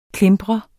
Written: klimpre

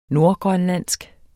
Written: nordgrønlandsk

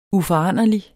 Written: uforanderlig

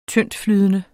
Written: tyndtflydende

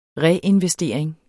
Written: reinvestering

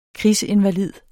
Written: krigsinvalid

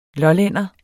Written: lollænder

